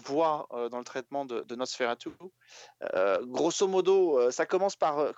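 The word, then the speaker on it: NOS4A2: Ça s'appelle NOS 4A2. Euh, mais qu'on peut prononcer Nosferatu hein, presque, euh, tellement le...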